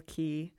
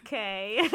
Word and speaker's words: Quay: right at the Lonsdale Quay. – Quay. I pronounce it as K. – I always pronounce it as Quay.